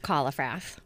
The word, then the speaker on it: Kallifrath: Sounds like Kallifrath. Sounds just like it's spelled. – I can say that. It's almost German. – So, Alsatian. – There we go. – So if you want to French it up, you can say Kallifrath.